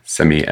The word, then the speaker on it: Simi: Simi, S-I-M-I.